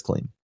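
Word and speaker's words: Klim: Do we pronounce it Clean? – It's Clean. He said it's Clean.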